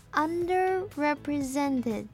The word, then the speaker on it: underrepresented: 第一个字是念成 underrepresented，它的重音是在 sent 的这个音节。